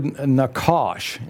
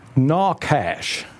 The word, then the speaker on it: nachash: It's Strong's 5175, and it's the Hebrew word nakash. In English, it's spelled N-A-C-H-A-S-H, and it means a snake from its hiss.